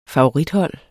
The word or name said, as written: favorithold